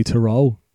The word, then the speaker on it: Theroux: So I stuck on, I think I've got his name right, Louis Theroux. – Yeah. – Theroux, Louis Theroux, anyway.